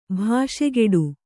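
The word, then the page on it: ಭಾಷೆಗೆಡು: bhāṣegeḍu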